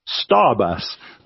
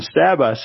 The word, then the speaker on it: Stabus: And they're called Starbus, but it's spelt one word, no spaces, hyphens, or anything to help clarify. It's S-T-A-B-U-S, Starbus. And Starbus have been fine, and Putco are in trouble.